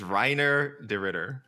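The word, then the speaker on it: Reinier de Ridder: He can explain where I'm mispronouncing Renier de Ritter. – Listeners, it is so much easier. It is Reiner de Ritter.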